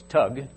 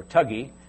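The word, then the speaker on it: thuggee: They call it, they pronounce the TH with a hard T, so it's tug or tuggy.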